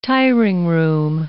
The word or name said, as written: tiring-room